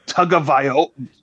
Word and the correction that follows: Tagovailoa: Tagovailoa. Tagovailoa. Okay.